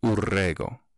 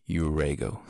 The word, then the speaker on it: Urrego: So if they can roll your R's, if you can roll your R's, then you can say Urrego. Because that's how you say my name in Spanish. But if you can't roll your R's, then you just say Urrego.